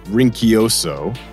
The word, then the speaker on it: Rinchioso: His name is spelled in a traditional English sense would be Michel or Michel. And his last name is Rinkioso.